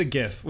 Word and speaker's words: GIF: It's a GIF with a hard G.